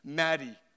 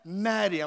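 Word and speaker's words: Mahdi: She goes, Maddie. I'm like, oh, I like that. She goes, no, but it's spelt different. She goes, It's M A H D I. I'm like, Oh, I like that. It sounds normal, but it's spelled different, right?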